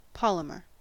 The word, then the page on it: polymer: American English